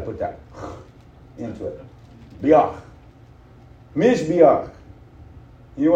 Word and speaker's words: Mizbeach: The word for altar is, and I hope I do it justice, Mizbeach. That's how they pronounce it. Mizbeach. M-I-Z-B-E-A-C-H.